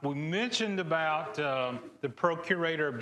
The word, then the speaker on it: procurator: And as a result of that, Caesar then gave him the responsibility uh, to be the procreator of, um, oh, did I say that right? I didn't say that.